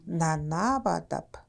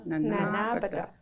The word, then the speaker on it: Ninanaamadab: So you say it like you really are sitting around. Ninanaamadab. – Ninanaamadab. – Yes, you know, you're relaxing. So you could say it as slow as you want.